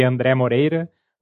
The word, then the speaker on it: André Moreira: It's like in plain Portuguese, we say André Moreira, but I understand that there are a few syllables there that are hard to pronounce depending on the place you're in.